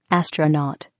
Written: astronaut

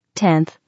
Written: 10th

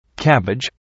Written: cabbage

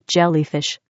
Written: jellyfish

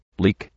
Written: leek

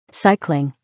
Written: cycling

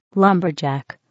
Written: lumberjack

